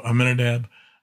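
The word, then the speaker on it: Amminadab: But Ram was the father of, this is insecurities, Aminadab. There we go. Ram was the father of Aminadab.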